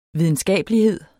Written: videnskabelighed

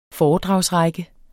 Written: foredragsrække

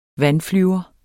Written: vandflyver

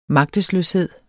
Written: magtesløshed

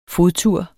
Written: fodtur

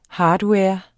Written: hardware

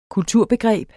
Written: kulturbegreb

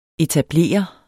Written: etablere

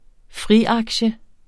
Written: FRIAKTIEN

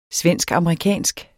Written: svensk-amerikansk

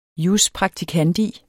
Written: jus

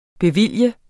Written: bevilge

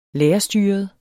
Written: lærerstyret

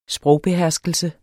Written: sprogbeherskelse

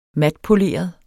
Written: matpoleret

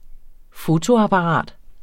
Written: fotoapparat

